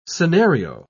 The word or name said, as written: scenario